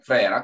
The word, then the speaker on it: Ferrara: E da Ferrara, come Frera, come si dice là,